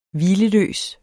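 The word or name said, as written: HVILELØS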